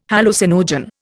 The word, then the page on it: hallucinogen: Indian